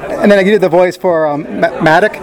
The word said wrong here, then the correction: MODOK: MODOK, which is a Marvel series.